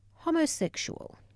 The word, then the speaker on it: homosexual: homosexual." She lingered over that last word, pronouncing it with the short O as in homily.